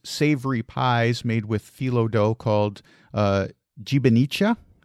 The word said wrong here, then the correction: gibanica: It's called gibanica. Gibanica. – And uh, gibanica, is a hard G.